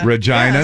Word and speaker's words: Regina: It was like my favorite place that I went to when I was up in Canada, Regina. – Regina. – Regina, yes. Saskatchewan. And yes, it is pronounced that way. There is no mispronunciation on that.